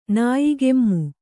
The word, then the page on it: ನಾಯಿಕೆಮ್ಮು: nāyi kemmu